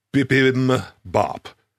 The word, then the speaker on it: bibimbap: Am I pronouncing that correctly? – Close. I think it's bibimbap.